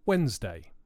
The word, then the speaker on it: Wednesday: The word Wednesday is difficult because it has a silent letter in the middle of it, the D.